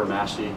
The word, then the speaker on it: Massey: I'm Chief Christopher Massey. Last name is spelled M-A-S-S-E-Y.